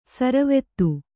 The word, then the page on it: ಸರವತ್ತು: saravattu